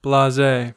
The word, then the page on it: blase: American English